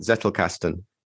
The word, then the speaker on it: Zettelkasten: So Zettelkasten, is that the right pronunciation for it? Zettelkasten. – As far as I know, it is.